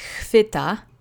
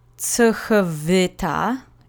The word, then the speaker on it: chwyta: So the last word is chvita. Remember, the CH letter combination is pronounced like an H, and you see the V sound again being softened into a F a little bit because it's so close to other consonants, and that usually happens when you see it that way. So it's not tsuchvita, it's chvita. Okay, let's say it together. And again, the accent goes on the penultimate syllable, so that's the first syllable in this case.